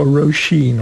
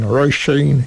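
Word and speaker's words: Róisín: Wymawi się Roisin w ogóle. Ja też długo nie widziałam, ponieważ ona z Irlandii i Irlandczycy inaczej wymawiają. – No właśnie. – Nie jest Roisin czy Roisin, tylko Roisin. Roisin się mówi.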